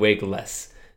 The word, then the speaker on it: Wiggles: But he said, yeah, it is pronounced like Wiggles.